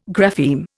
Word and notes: grapheme: Indian

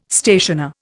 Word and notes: stationer: Indian

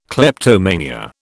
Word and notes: kleptomania: British English

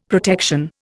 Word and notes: protection: Indian

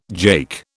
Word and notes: jake: British English

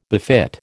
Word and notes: befit: American English

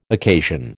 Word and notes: occasion: American English

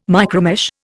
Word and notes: micromesh: Indian

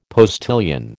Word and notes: postilion: American English

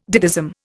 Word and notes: ditheism: Indian

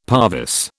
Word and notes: parvis: British English

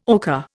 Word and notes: ocker: Indian

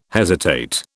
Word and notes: hesitate: British English

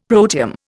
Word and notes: protium: Indian

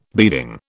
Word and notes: beading: British English

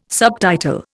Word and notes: subtitle: Indian

